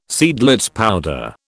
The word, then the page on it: Seidlitz powder: British English